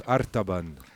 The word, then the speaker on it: Artaban: Et ça se prononce Artaban.